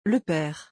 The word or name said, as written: Le pere